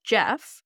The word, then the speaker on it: Gef: Shortly after this took place, the entity behind the walls introduced itself properly to the family as Jeff, which it's spelled G-E-F.